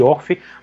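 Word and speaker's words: Orphée: É, é porque assim, o, o, o Orfe, Alan, é como se fala o nome do Orfeu no francês.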